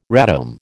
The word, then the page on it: radome: American English